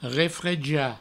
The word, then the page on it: refrejar: refreja